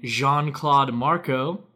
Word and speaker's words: Jean-Claude Marco: Four others had 2 goals, and Jean-Claude Marco, am I saying that right? – That sounds right.